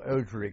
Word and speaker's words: Ojhri: and I joined them at a place called Ojhri Camp. – How do you spell that? – O-J-H-R-I. – Ojhri. – O-J-H-R-I. Ojhri Camp,